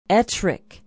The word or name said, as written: ettrick